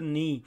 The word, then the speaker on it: knee: In the middle of your leg, you have your knee, and don't pronounce the K letter, okay? Just the knee.